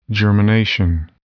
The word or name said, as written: germination